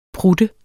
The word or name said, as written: prutte